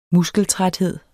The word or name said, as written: muskeltræthed